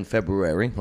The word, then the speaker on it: February: But in February, last month, don't forget there's two R's in February, okay, say it right.